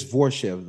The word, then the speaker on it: Borshchev: Because he was like Borshchev. Borshchev is like how Paul Felder was pronouncing it. – Yeah, it's Borshchev, though.